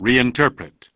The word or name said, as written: Reinterpretation